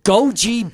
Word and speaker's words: goji: and goji berries. I hope I'm saying that right. G-O-J-Y. Did you ever hear that? Goji? – I think that's correct.